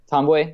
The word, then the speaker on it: Ntambwe: Is Joel, our guy Damon mentioned Joel T- Babu? Is that how you say it? Ntambwe, yeah. Ntambwe, sorry, that's I'm so terrible. – I I figured the N and T.